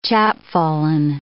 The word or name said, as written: chapfallen